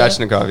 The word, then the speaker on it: Svechnikov: That's how you say his name? – Svechnikov, yeah.